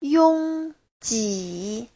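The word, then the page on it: yongji: yong1ji3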